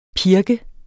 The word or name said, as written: pirk